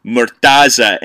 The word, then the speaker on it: Murtaza: But it's Murtaza, M-U-R-T-A-Z-A Hussein, from The Intercept.